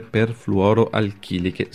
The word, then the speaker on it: perfluoroalchiliche: Intanto, che cosa sono queste sostanze perfluoroalchiliche, se l'ho detto giusto? – Sì, giustissimo, se ne conoscono almeno 12,